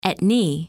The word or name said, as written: Aitne